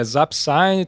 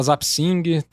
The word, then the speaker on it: Zapsign: É, vai, é Zapsign, tem gente que fala Zapsing, tamo topando tudo.